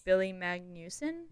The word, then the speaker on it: Billy Magnussen: Billy Magnussen? – Yes.